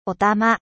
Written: louche

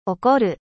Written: colere

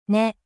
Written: 根

